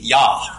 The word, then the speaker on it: ja: You know, the way a German would say yes would be ja, right? Right? You know how they spell that? They spell that J-A. So in German, a J sounds like a Y.